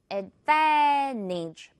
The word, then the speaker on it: advantage: Advantage. T는 발음상 없습니다. 생략할 거예요. Advantage.